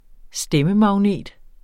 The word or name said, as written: stemmemagnet